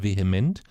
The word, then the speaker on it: VHEMT: Das heißt, ähm, ausgesprochen, also abgekürzt VHEMT, aber ausgesprochen wird es vehement, ist eine Bewegung, und zwar eine Bewegung von Menschen, die sich um das Leben auf dem Planeten Erde sorgt.